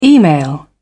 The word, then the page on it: email: British English